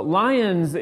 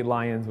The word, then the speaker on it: Lyon: So Lyons is actually more north France. So obviously Lyons was a mispronunciation of Lyon.